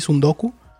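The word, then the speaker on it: tsundoku: Pero hay un término que se usa mucho también para describirlo, que viene del japonés y se llama el sundoku. ¿Sundoku? No se dice... creo se pronuncia así, sundoku. Pero se escribe con una T al principio, T, sundoku, sundoku, con sundoku. K, U, sundoku.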